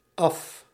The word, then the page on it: from: /frɒm/; /frʌm/; /frɑm/; /frəm/